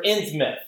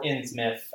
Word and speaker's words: Innsmouth: Or Innsmouth, right? It can be pronounced Innsmouth or Innsmouth. I think, like, the accepted term, the accepted pronunciation is Innsmouth.